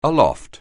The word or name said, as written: aloft